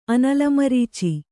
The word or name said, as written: ಅನಲಮರೀಚಿ